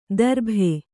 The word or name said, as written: ದರ್ಭೆ